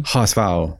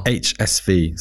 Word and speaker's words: HSV: HSV. HSV. So that's Hamburg Sports Club. – Right. – But the Germans say HSV. And I got corrected on this by my brother-in-law, who's a big HSV fan.